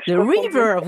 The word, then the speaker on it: The River: Le numéro 5, The River, est-ce que j'ai bien prononcé avec l'accent anglais The River. The River, vous avez raison.